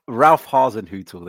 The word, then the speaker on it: Hasenhüttl: Ralph Hasenhüttl.